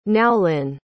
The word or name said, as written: naolin